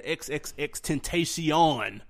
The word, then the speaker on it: XXXTentacion: We had ASAP Mob, and we had XXXTentacion um, that's how you say it.